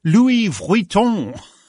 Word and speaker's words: Vuitton: How do you pronounce that? Louis who? – Louis Vuitton. – Vuitton. – Louis Vuitton, yeah.